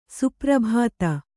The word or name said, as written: ಸುಪ್ರಭಾತ